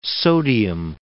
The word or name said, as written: sodium